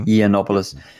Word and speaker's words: Yiannopoulos: Milo Yiannopoulos, is that how you pronounce it? – Mm-hmm. – Yiannopoulos.